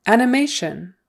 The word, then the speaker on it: animation: Animation, animation. Accent on the third syllable. Sorry, the stress is on the third syllable. Animation. And again, the T-I-O-N is pronounced with an S-H and then like a U, short U-N.